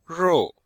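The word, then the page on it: rou4: tone 4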